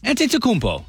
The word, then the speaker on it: Antetokounmpo: So, Giannis Antetokounmpo. – You know what? Close enough. Antetokounmpo.